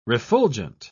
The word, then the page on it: refulgent: British English